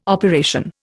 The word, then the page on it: operation: Indian